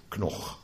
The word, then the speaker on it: Knoch: Maar goed, volgens dat vooropgezette idee van meneer Knok hebben ze allemaal een verschillende betekenis. Je schrijft het dus K-N-O-C-H, dan weet je dat, hè? Knok.